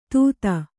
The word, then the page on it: ಧೂತ: Kannada